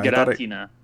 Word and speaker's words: Giratina: Giratina. You gotta roll the R a little bit.